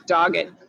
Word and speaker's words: Doggett: Did I say the last name right, by the way? Is it Doggett? – You said it way nicer than it's actually pronounced, which is just Doggett.